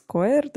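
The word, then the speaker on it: Coyier: Перейдем, да, вот, в общем-то, как раз-таки плавно переходим э, к теме, э, которую освещал Крис, э, Крис Койер, да, наверное, так его фамилия правильно произносится, а Крис Куэр — это...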